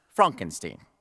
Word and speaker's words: Frankenstein: That's Frankenstein. My name. It's pronounced Frankenstein.